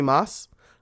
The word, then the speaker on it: Maass: Today, my guest is Corey Maas.